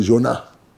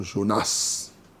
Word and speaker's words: Jonas: Il y en a qui disent Jonah. Ils disent le S là, ils ne prononcent pas. Il y a les villageois comme moi qui disons Jonas.